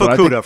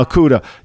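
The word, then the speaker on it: Okudah: And I think this conversation about Okita, I'm just saying his name correctly. – Yeah, Jeff Okuda from Ohio State. – Okuda.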